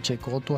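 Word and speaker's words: Cecotto: Bueno, naturalmente, como lo denota claramente su apellido, Checoto, que se escribe Secoto con doble T.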